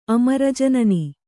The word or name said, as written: ಅಮರಜನನಿ